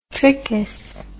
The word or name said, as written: trichous